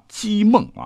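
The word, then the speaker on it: 剧孟: 他叫姬梦啊，这个姓比较少啊，是剧喜剧的剧啊，但是要念成姬，是当时的洛阳一带有名的侠客了哈，常常是爱打抱不平、扶弱济困，喜欢收留无家可归和难容于世的豪侠义士，不问姓名，折节相交啊。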